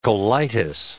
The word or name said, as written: colitis